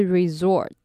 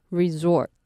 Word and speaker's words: resort: 那第一个是 resort， resort，那这个单字的 S 要念成 Z 的发音。